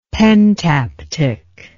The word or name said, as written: pentaptych